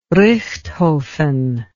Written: richthofen

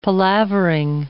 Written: palavering

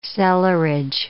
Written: cellarage

